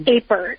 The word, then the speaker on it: Apert: Is that how you pronounce it? Apert syndrome? – Apert.